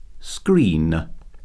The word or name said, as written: screen